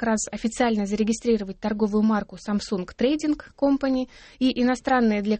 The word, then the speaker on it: Samsung: Samsung, как мы уже сказали, в транскрипции правильно произносится Samsung, означает «три звезды», и связь такого названия, скорее всего, возможна с тремя сыновьями основателей компании.